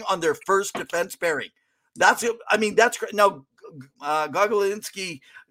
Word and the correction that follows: Goligoski: I can't even say his name. – Goligoski. – Thank you.